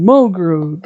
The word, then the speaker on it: Mulgrew: So we start off this episode introduced to Kate Munn. Kate Mulgrew. Mulgrew. There we go.